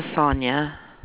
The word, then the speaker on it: Sonya: My name is Sonya. – Sonya, and how do you spell that? – S-O-N-Y-A.